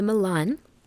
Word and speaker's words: Milan: I'm playing Sonia Milan, like the city in Italy.